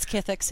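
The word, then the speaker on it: Kith'ix: But the creature that he woke, the Chithraxi, I think its name is Kithix. – It's Kithix, yeah.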